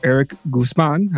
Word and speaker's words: Erik Guzmán: But we do have a very special guest, Erik Guzmán.